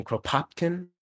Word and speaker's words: Kropotkin: And I also recommend reading, he's another dusty dead socialist, Peter Kropotkin. I'm getting his name wrong. It's Russian, Kropotkin.